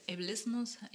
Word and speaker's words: Ableism: Sexarbeit, ähm, Ab Ableismus, Ableism heißt es, glaube ich, äh, richtig ausgesprochen heißt das, ähm, wird dazu gehören.